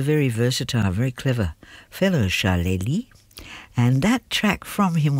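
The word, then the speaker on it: Charlélie: That was called Drifted, and it was from Charlie Lee, unusual name, C-H-A-R-L-E, accent on the E, L-I-E, Charlie Lee.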